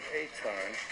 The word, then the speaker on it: Etan: Well, they pronounce it Etan. – Well, when you go this long or something like this, you do. You feel like you're looking for your own son. – The search for Etan dragged on.